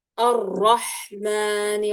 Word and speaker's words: الرحمن: لا هنا عندي اللام، الراء مدغمة في اللام، يعني لام شمسية لا تنطق اللام. الرحمن الرحيم.